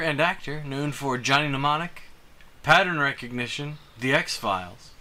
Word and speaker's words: Mnemonic: Mnemonic. The M is silent.